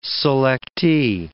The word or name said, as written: selectee